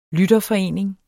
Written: lytterforening

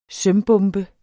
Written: sømbombe

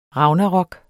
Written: ragnarok

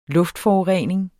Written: luftforurening

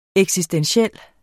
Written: eksistentiel

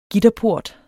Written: gitterport

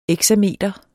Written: exameter

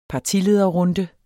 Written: partilederrunde

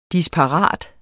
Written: DISPARATE

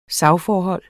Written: SAGSFORHOLD

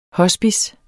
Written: hospice

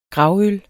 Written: gravøl

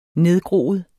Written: nedgroet

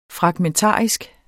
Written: fragmentarisk

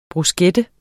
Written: bruschetta

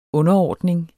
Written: underordning

